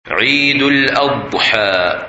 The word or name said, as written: Eid Al Adha